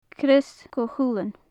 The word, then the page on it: crois chuchulainn: gaelic